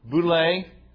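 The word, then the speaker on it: Boule (βουλή): Boule, in English, B-O-U-L, long A sound, okay.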